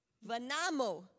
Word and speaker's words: Värnamo: In Vanamo. Vanamo. Vanamo. Vanamo. – I Vanamo. wrote it the way yeah, it's pronounced.